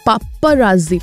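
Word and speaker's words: paparazzi: भाई पापराजी पेपराजी जैसे भी प्रोनाउंस करते हैं, मैं इतना इस पर ध्यान नहीं देती, लेकिन ये जो है ना, इनको बस कहीं से फालतू की चीज़ें चाहिए कि अपना खुद का गॉसिप बना लें।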